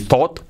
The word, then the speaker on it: thought: Pensar en pasado, thought, no, pronunciación, thought. No es that ni thought, es thought. La mitad entre O y A.